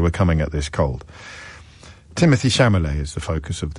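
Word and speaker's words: Timothée: Timothy, that's how you pronounce his first name, by the way.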